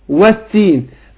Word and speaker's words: والتين: هنا برضو في التين اقول والتين، هنا اضغمت اللام في التاء، فبنطق بعد الواو على طول ايه التاء.